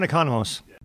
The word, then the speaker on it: Economos: It looks like economics. – Yes, John Economos.